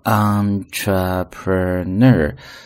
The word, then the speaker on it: entrepreneur: Entrepreneur 是一个名词，企业家或者创业家，叫做 entrepreneur, 重音在最后一个音节上，读作 entrepreneur, 是第一个人。那么第二个人呢，就是王凯所扮演的这样的一个人，他是一个 a handsome doctor, 一个非常帅的一个医生啊， handsome 形容词，非常帅的。